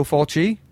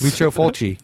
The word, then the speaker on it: Fulci: No, it's the way he accents it heavy on the chi. – Lucio Fulci. – Yeah, Fulci, not Fulci.